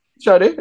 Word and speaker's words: Sade: I'm saying it wrong. Man, Sade, right? – Sade, yeah. – Blake saying Sade. I'm saying Sade. It's Sade. – It's Sade, yeah. I thought it was Sade.